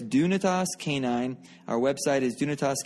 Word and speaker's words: Dunetos: That's spelled D-U-N-E-T-O-S, Dunitas. The first question I get is, how do you pronounce it? You pronounce it Dunitas.